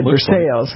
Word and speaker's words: Versailles: And so I said, Versailles. And he said, it's Versailles.